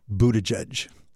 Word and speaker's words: Buttigieg: I guess, first of all, I think it's pronounced Buttigieg. If you look at the spelling, you would never guess any of this. But anyhow, so I think it's Buttigieg.